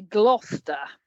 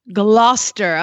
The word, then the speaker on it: Gloucester: I am in southwest England near Gloucester. – Gloucester.